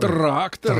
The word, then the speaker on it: Тракторы: Тракторы. Трактор, тракторы. Именно так правильно произносить это слово во множественном числе.